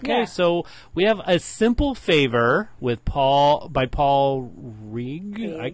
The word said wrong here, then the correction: Feig: Paul Feig.